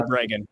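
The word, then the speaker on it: Breggin: Yeah, as far as, there's also a debate out there going on between Matthias Desmond and Peter Bregan. Did I get the name right? – Peter and Ginger Bregan. Yes.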